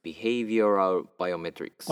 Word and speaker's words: Behavioral Biometrics: Behavioral Biometrics。